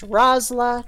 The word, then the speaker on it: Rosyla: The Y in my name is silent, so it's pronounced Rosyla, not Rosyla.